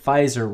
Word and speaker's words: Pfizer: And so, by the way, it occurred to me that Pfizer rhymes with Kaiser.